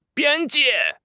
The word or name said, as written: 边界